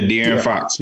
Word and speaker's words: De'Aaron Fox: The agency represents DeAndre De- De'Aaron, De'Aaron Fox of mm-hmm. the, uh, that's just correct. I said it right. – Yeah, De'Aaron Fox.